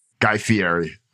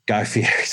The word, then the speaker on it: Guy Fieri: guy fiera is that how you say his name guy Guy Fieri. Guy Fieri, sorry.